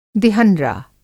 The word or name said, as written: दिहंरा